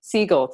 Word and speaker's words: Segel: The one with Jason's, is it Seagull? Seagull? I don't even know how to say his last name. – His name said out loud? – I forget how he says it. – Jason Segel. – Segel, thank you.